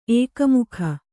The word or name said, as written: ಏಕಮುಖಿ